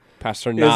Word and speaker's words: Pastrnak: It's pronounced knock. Pastrnak.